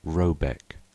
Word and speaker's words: Rabaeck: The manor house, or Herrgård, in question, is to be called R-A-B-A-E-C-K, pronounced something like Robeck, though that is not its name.